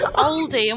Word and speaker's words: Aldi: You're not even pronouncing them right. One's Aldi and one's Audi.